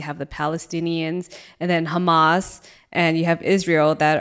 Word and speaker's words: Hamas: Hamas.